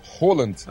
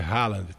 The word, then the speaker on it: Haaland: Não, todo mundo fala que a pronúncia correta do nome em norueguês é Holland. – Ah, mas como eu tô no Brasil, cara, vai ser Haaland, tá bom?